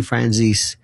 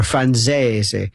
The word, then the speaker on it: Franzese: Michael Franzese, by the way, Sonny Franzese, or Franzese, if nobody ever pronounces the name correctly, but I mean, Michael Franzese, Sammy the Bull, others, they first started this thing, and now Franzese has transcended that.